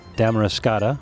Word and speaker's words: Damariscotta: What a beautiful setting here in the town of Damariscotta.